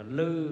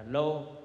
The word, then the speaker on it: lô: Chữ này thì chúng ta đọc là lư, nhưng mà thường các cụ xưa nay đọc là lô. Lô hương với lư hương thì cũng đều một chữ này.